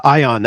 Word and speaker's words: Aion: So when you said Ion, that's A-I-O-N. – Yes, I think I'm the only person that pronounces it that way, but the A is silent for philosophical reasons.